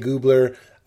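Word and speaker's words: Gubler: We have Colin Hay, Matt Nathanson, Topher Grace, Kenny DeForest, Ashling B, Matthew Gray Goobler. I always feel like I'm saying that wrong, but it is Goobler.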